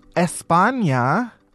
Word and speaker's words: España: First, a man was arrested in España, that's how the locals say it, after causing a terror alert because he tried to win his ex back with a pineapple stuffed with nails and pins.